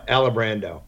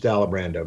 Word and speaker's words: Alibrando: Alabrando. It's just Alabrando, man.